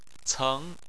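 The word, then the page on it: ceng: tone 2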